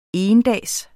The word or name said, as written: endags